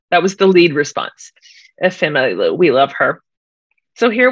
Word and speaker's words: Ifemelu: Here's the thing. Even though it's spelled differently, it's actually pronounced Jennifer. – It isn't. Stop it. – Ifemelu. – Stop it right now. Say it again. – Ifemelu.